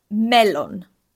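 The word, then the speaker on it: melon (μέλλον): So is melon. – Melon. – Yeah, like melon. like the like the fruit. And I'm always saying to my students, okay, just remember, you know, like a melon.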